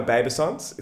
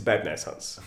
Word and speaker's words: babe naissance: It's not a babesance, it's a babe naissance.